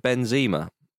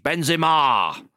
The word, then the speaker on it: Benzema: And I think that you go with Benzema. That's how you pronounce his name. Whereas Darren Fletcher on comms for BT Sports very much. Benzema.